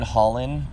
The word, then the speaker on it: Hollon: Just type in Pathfinder Justin Holland. The last name is spelled H-O-L-L-O-N.